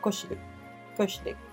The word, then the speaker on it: koselig: En Noruega el término es, se escribe koselig, e K-O-S-E-L-I-G y por lo que estoy buscando en YouTube, aprender a pronunciarlo, no, sé si me va a salir bien, pero es como Koshlek, algo así.